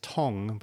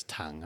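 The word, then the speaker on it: Tongue: I mean, the commentators were pronouncing his name as Tong, but I prefer it as Tung.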